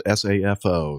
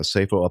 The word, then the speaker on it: SAFO: The FAA issues a SAFO, I guess is the way you pronounce it S A F O, SAFO, SAFO, following recent close calls.